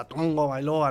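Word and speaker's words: Tagovailoa: También, por ejemplo, pronuncia Tango Bailoa está bien, Diego, de alguna forma el, apellido es complicado, ¿no? de pronunciarlo, porque se pronuncia como si fuera Tongo, ¿no? Así con la garganta, Tongo Bailoa, ¿no? Tongo Bailoa.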